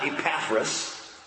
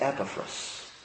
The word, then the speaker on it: Epaphras: There's a correct pronunciation, and I think we owe it to this man to pronounce his name correctly. It's not Epaphras, it's Epaphras.